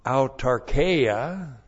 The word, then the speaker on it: autarkeia: And then we have the noun autarkeia, A-U-T-A-R-K-E-I-A.